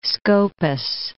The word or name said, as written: Scopus, Mount